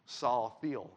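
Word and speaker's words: Salathiel: Again, Saul Thiel, right, the Greek pronunciation.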